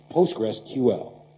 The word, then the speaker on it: PostgreSQL: Wer wissen will, wie man das ausspricht, es gibt auf der Webseite eine hübsche MP3-Datei. – PostgreSQL. – Also so spricht man es offiziell aus. Das ist von einem der Postgres-Erfinder, diese Aussprache.